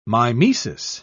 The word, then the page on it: mimesis: British English